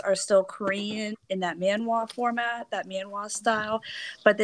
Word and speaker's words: manhwa: It was a Korean manhwa, not manga, manhwa, I think. – It's manhwa. – Manhwa? Yeah. I did say it right.